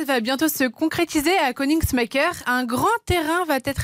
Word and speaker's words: Koenigsmacker: Alors, Königsmacker. – Ça s'écrit, écoutez bien, K-O-E-N-I-G-S-M-A-C-K-E-R. Et sans accent? – Königsmacker.